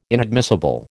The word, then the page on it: inadmissible: American English